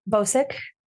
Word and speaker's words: Bocik: Bocik.